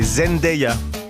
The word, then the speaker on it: Zendaya: Par contre, j'ai appris qu'il fallait prononcer Zendaya.